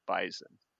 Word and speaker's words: bison: And as you can always tell, a fellow North Dakotan because of how we pronounce bison. You say it with a Z, not with an S.